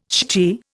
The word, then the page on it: shchi: Indian